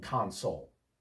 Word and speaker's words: console: I have an Xbox gaming console. Console with the accent on the second syllable is a verb meaning to comfort someone.